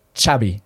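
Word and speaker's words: Xavi: In Catalan, it's X, and they pronounce it Chabi, like a CH almost, right?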